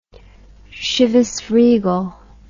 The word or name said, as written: Chivas Regal